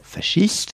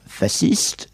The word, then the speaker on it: fasciste: Écoutez, il y a deux façons de dire. Il m'arrive d'ailleurs de passer de l'une à l'autre en fonction des interlocuteurs. On peut dire fasciste, ce qui est la dérivée directe du fascisme italien, qui est probablement la prononciation la plus fréquente aujourd'hui. Ou on peut dire fasciste, ce qui dérive davantage du mot français faisceau,